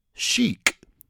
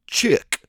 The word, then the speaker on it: Chic: We're going now to a slightly more contemporary track by a group called Chic. Sometimes mispronounced as Chick, but they are considered a disco group.